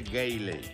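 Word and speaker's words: Greeley: Ahí le va para que vea, Y me manda un saludo allá para Grilly, Colorado. – ¿Para Grilly? – Sí, no, para Memo y Pepe. – Grilly, Greiley, Colorado, se escribe Greiley.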